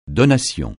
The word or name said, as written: donation